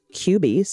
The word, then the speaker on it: Cubii: Thanks to my new QB, pronounced QB, C-U-B-I-I.